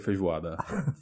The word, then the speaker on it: feijoada: I never ate feijoada in my life. – I mispronounced it. – No, you didn't. You did right. It's okay. Called feijoada.